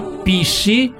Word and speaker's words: PSSI: Ma va bene, mandiamo subito l'inno del PSSIP, sì, Partito Super Sovranista Italiano, PSSI. Si pronuncia Pissi, super sovranista italiano, ti difende ogni giorno di più.